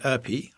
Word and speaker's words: Ypi: And I chose this because of a book that my son lent me called Free by Leah Erpi. I think that's how we pronounce it. – Y-P-I. We've put it into a Google pronunciation thing and that's our best bit.